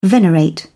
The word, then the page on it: venerate: British English